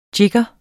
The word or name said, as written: JIGGERE